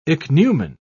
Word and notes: ichneumon: British English

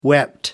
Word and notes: wept: American English

